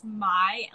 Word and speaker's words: Mai: I just want to say my name's pronounced Mai, like NY.